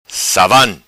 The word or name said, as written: Savan